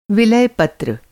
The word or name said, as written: व